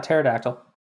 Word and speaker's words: pterodactyl: Yeah, pterodactyl.